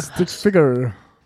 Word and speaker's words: Stick Figure: Alok und Stick Fiction. Ja, da haben wir es wieder, du kannst es besser aussprechen. – Stick Figure. – Ja. – Figure, also nicht Ficker, sondern Figure.